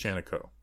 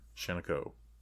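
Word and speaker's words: Shiancoe: It's Vasante Shaniko, I think. – Shaniko. – It's correct pronunciation. – Shaniko. Texaco.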